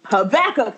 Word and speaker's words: Habakkuk: Jonah, Micah, Nahum, Habakkuk. – Habakkuk, yes, say it right.